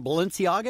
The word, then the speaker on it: Balenciaga: This shirt from Balenciaga, is that how you say it, Dave? – Yes.